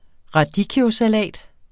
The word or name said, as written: RADICCHIOSALAT